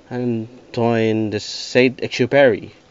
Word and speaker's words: Antoine de Saint-Exupéry: And after a while, I purchased a book I've always wanted to buy a while ago, titled The Little Prince by Antonio, or how do you pronounce it? Antoine de Saint-Exupéry, French.